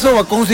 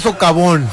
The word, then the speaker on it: socavón: Y es culpa también del sobacón que se abrió ahí a un lado de las vías del tren. – Canícula, y no se dice sobacón, se dice socavón.